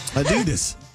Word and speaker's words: Adidas: What? That's how you're supposed to say it. – And nobody say Adidas. – It says Adidas. – You stress on the second syllable. – Okay. – Yes, because it's Adidas.